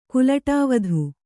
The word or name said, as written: ಕುಲಟಾವಧು